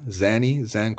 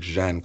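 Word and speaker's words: Žan: Our young boy from Slovenia, Zan Kolmanic. I think it's pronounced like Jan Kolmanic.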